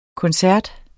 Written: koncert

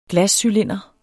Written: glascylinder

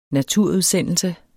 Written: naturudsendelse

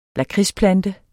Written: lakridsplante

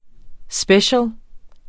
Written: special

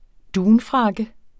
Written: dunfrakke

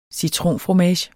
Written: citronfromage

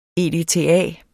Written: EDTA

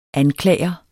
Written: anklager

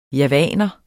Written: javaner

